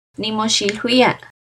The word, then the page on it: Nimoxilhuia: Náhuatl